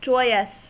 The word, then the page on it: joyous: American English